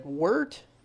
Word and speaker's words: wort: It's the liquid that you have in the sink Once you steep that first bag of grains, that becomes wort. – It is wort. – That word wart in your recipe. – It's pronounced wart.